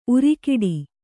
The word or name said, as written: ಊರೆಗೊಡು